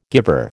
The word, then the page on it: guiver: American English